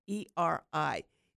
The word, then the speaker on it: Pteridomaniac: So I just love that word, and it's spelled P-T. Pteridomaniac.